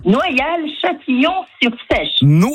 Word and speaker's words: Noyal-Châtillon-sur-Seiche: Noyal-Châtillon-sur-Seiche.